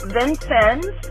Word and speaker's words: Vincennes: So first of all, Tiny, the town is actually pronounced Vincennes.